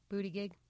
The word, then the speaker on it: Buttigieg: Like Marianne Williamson is going to have different audience than Buttigieg, or I still can't say his name, than Mayor Pete. – Buttigieg? – Yeah, Buttigieg. So